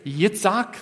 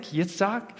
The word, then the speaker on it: Yitzhak: The word for laughter in Hebrew here is Yitzhak. That's the Hebrew pronunciation of Isaac, Yitzhak.